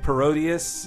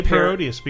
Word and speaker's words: Parodius: Well, I guess I say Parodius because it sounds like Gradius. – Mm. Mm, but I guess Parody plus Gradius would be Parodius.